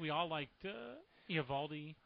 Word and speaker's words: Eovaldi: They say Eovaldi on TV. – Eovaldi is, I think you're right, they make that one syllable.